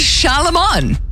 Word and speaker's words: Charlemagne: It's Charlemagne. Say it right.